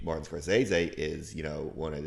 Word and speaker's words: Scorsese: You know that's not how his name's pronounced. – Oh, no, how? – Because I Scorsese. – Scorsese. Okay. Scorsese. Something like that. And it's him saying it.